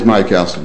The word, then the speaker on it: myocalcin: So I understand your primary reference or primary prior art is the myocalcin. Am I not pronouncing that right? Probably. – It is myocalcin.